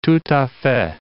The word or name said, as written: tout a fait